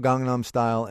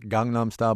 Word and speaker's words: Gangnam: Not Gangnam Style, but Gangnam Style? – The word in Korean, the letter is kind of halfway between a G and a K.